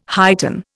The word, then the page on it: heighten: Indian